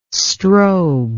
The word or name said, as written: strobe